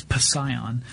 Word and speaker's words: Psion: But and Scion, by the way, is spelled with a P-P-S-I-O-N, so it's Psion.